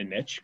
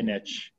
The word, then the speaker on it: niche: Find a niche or niche. People get mad. – I don't know how to say it. – People like get offended. Like, why do you pronounce it incorrectly?